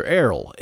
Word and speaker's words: Airl: But once again, Errol, that's how you pronounce her, Errol, A-I-R-L.